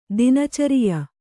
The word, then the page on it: ದಿನಚರಿಯ: kannada